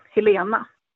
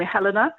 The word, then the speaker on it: Helena: I'm from Sweden, so the pronunciation in Sweden would be Helena. My English friends and family call me Helena, but a lot of the people from the US call me Helena, and both pronunciations are just fine.